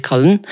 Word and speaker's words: Conlon: My guest this week is Liv Conlon.